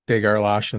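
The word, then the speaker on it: de Gerlache: I think a lot of, you know, there's some debate about whether it's a hard G or a soft G.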